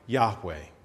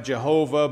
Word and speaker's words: Yahweh: Probably pronounced something like Yahweh. Some of the older translations might have Jehovah, but the scholars have sort of, after study, decided that's probably not accurate. So it's probably Yahweh, which, if it's translated, just basically means closely related to I am.